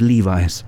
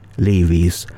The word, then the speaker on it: Levi's: Wrangler, Lee und Levi's. Die ersten beiden Markennamen wurden korrekt ausgesprochen, mal abgesehen von dem stimmlosen W in Wrangler. Levi's allerdings wurde im Osten gesprochen, wie es geschrieben wurde. Levi's.